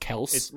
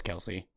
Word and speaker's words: Kelce: It's like Travis Kelce saying, no, it's actually pronounced Kels. – And everyone just like no. thought about it for a minute. Went, no, it's Travis Kelce.